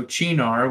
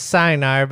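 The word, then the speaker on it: Cynar: You might also hear it pronounced as Sinar, but apparently Roukan has been schooled on this.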